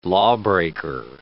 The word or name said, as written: lawbreaker